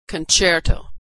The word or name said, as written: concerto